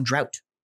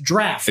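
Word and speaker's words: draught: And so people will come in and they think they're being slick and be like, oh, what's on drought? It's still pronounced draft.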